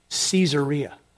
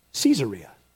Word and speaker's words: Caesarea: By the way, when I was in Israel, my Israeli guide pronounced that Caesarea. Caesarea. There's a reason for that.